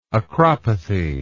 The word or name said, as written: acropathy